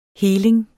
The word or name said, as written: helinger